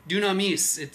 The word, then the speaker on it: dunamis: The word power is dunamis.